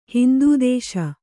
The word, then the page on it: ಹಿಂದುದೇಶ: hindu dēśa